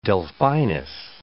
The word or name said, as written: Delphinus